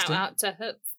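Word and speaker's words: Höfn: And then, of course, I got stuck in a town called Hup, spelled H-O-F, with a little accent on the O.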